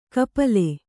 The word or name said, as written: ಕಪಲೆ